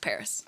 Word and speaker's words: PVRIS: But it's spelled P-V-R-I-S, but it's pronounced Paris.